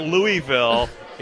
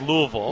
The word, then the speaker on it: Louisville: More importantly, if you're watching the Cardinals on TV, it's not Louisville, it's Louisville.